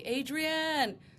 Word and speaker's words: Adrienne: I always go back to the Rocky movie. Adrienne, that's exactly how you pronounce it.